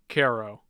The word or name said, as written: cairo